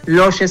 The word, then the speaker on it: Loshe: Sí, estoy en todas las redes sociales y estoy como Soy Loshe. Loshe se escribe L-O-S-H-E.